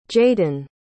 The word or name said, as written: jadyn